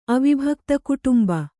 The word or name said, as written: ಅವಿಭಕ್ತಕುಟುಂಬ